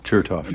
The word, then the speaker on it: Chertoff: Chertoff, I think that's how you say it. – Chertoff, yeah.